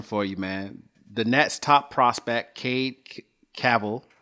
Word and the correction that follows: Cavalli: Cavalli, actually, man. it's Cavalli. I'm sorry, Cavalli.